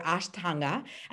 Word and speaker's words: Ashtanga: Helena Vesic is an amazing, amazing, amazing, amazing yoga teacher, Ashtanga.